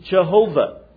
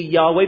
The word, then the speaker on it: Yahweh: Jehovah. Most of the scholars and rabbis believe that the proper pronunciation would be Yahweh, but they're not totally sure.